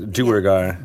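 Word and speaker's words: Duergar: I always pronounce it Dwergar. Is that wrong? – I do too. I like the way he pronounces it, but Dwergar. – I say Dwergar. – Dwergar. – But these are all made up words anyway. – Yeah. So I'm glad I got an ally though. So it's Dwergar.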